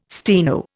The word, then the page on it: steno: Indian